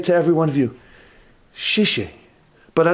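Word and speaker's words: shishi (谢谢): He said, shishi. Is that how you say it? – Yeah.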